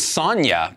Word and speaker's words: Sanja: I believe it's Sonya, but I may be wrong. – I think the J is silent. Just with the J, I'm just like, yeah, Sonya.